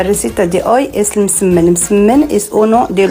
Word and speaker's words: msemen: El ergaif marroquí, por cierto, recibe también el nombre de misemen. O algo así. Una palabra extraña. Es una M líquida, misemen, ¿vale? – Bueno, una M líquida.